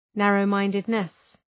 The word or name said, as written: narrow-mindedness